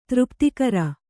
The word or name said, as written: ತೃಪ್ತಿಕರ